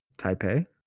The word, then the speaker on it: Taipei: Taipei.